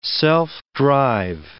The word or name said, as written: self-drive